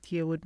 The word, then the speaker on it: Yearwood: My name is Charlotte Yearwood Martin,